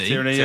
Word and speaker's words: Tierney: Certainly if we get, and we all don't know how to, is it Tierney? How are we pronouncing? – Tierney, yeah.